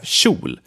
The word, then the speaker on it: kjol: Then we have kjol, which is spelled with K-J and means skirt. Kjol.